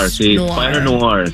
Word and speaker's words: Spider-Noir: Spider-Noir.